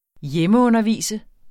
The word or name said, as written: hjemmeundervise